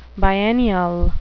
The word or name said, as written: biennial